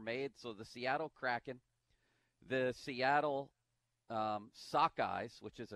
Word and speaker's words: Kraken: This Kraken, I thought it was Kraken. It's a mythological fish that's pronounced Kraken.